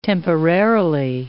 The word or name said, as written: temporarily